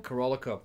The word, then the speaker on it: Carolco: Carolico. How do you say it? – Corolco.